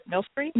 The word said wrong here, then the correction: Milstein: Milstein. – Beth Millstein.